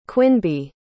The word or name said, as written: quinby